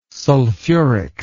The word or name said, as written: sulfuric